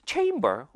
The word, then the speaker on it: chamber: Chamber, 이거 많이 들어봤는데요. Chamber이라고 하면은 이거 읽을 때요. Chamber 아니고요. Chamber, A라고 읽습니다.